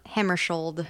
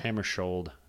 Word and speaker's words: Hammarskjöld: Hammarskjöld. – Hammarskjöld. There's a K and a J that make a ch sound.